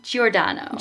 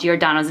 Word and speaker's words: Giordano: How do you say it? Giordano. Giordano. Giordano I, Bruno. How I think of it, have you heard of the restaurant Giordano's? No.